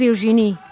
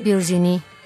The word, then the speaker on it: Virginie: ¿Quién es Virginie? ¿Lo pronuncié bien? – Sí. – Virginie.